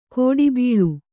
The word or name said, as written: ಗಿಡಬೀಳು